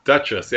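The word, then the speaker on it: Douches: Did I say that right? His last name? – Yeah, Duchess. Yeah.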